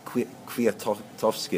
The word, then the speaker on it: Kwiatkowski: And interestingly, just remembering back to what Cavendish said, the Kwiatkowski, is that how you pronounce his name? – Yeah. – Kwiatkowski, his Polish young Polish teammate who's still in the white jersey.